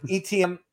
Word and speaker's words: Etienne: Etienne.